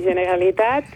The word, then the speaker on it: Generalitat: ¿Cómo se pronuncia? – Generalitat, ¿estoy diciendo bien? – Sí, Generalitat, y el presidente se llama Carles Puigdemont y Casamayor.